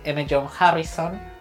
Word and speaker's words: M. John Harrison: Es em, Deberías venir conmigo ahora, un libro de cuentos de M. John Harrison. Lo dije bien, ¿no? Sí.